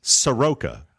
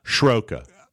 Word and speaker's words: Soroka: So the proper pronunciation is Soroka, correct? – That's how I pronounce it. – There it is. Yes. And that's how has mispronounced it. – Yes, I have. And apologies. – By putting an SH sound on it, it's not Schroka.